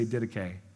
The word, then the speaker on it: Didache: Everybody say Didache. The Didache. It's spelled Didache, but it's Didache. It means teaching.